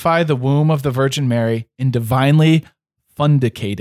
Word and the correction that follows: fecundate: it, fecundate it. Fecundate. – And then I think too, in my pronunciations, it could you could do a long E. Fecundate, I believe.